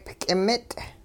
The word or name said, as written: k'imit'